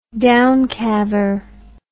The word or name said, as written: downcalver